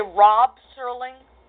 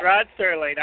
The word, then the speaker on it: Rod Serling: Did you say Rob Serling? – Rod. – All right. I thought you said Rob, because I was going to smack you down if you said it his name wrong.